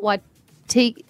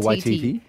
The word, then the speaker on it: Waititi: No, is that how, did I say that right? – I'm not sure. What's he? – Rita Waititi. Taika Waititi. Yes. Rita Waititi Ora.